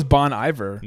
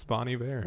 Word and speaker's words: Bon Iver: I thought it was Bon Iver. – Nope, it's Bon Iver.